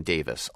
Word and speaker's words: Davies: And Ray Davies and his brother Dave Davies, apparently the family pronounces that last name Davis, although no one else anywhere in the world pronounces it that way.